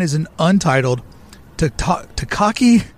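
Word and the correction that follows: Taika Waititi: Oh, Taika. – Taika. Watati. How do you say? – Taika Watiti. – Oh, yeah. So close, Philander.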